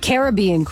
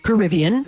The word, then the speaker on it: Caribbean: That's Caribbean Queen or Caribbean. – Yeah. – Depending on how you say it.